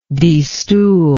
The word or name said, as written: destool